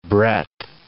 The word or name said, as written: breadth